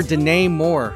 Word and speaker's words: Denai: This is the singer Danae Moore. Her first name is spelled D-E-N-A-I.